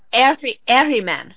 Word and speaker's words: Ahriman: Is it Ahriman? – It's Ahriman.